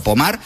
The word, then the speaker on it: Pomard: Se escribe Pomard, como si fuera en castellano, pero Pomard, pues allí en Francia, hablamos de unas imágenes.